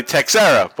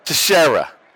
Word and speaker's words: Teixeira: Like, Mark Tech, we would say Texera, but it's Mark Teixeira.